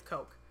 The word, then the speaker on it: Koch: Is it Koch? I always pronounce it Koch in my head. – It's Koch.